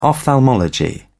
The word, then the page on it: ophthalmology: British English